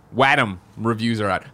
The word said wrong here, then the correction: Wattam: He says it's Wattam.